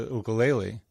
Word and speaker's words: ukulele: Or even more recently, I started playing the ukulele. I use the Hawaiian pronunciation for those of you that think I'm saying it weird.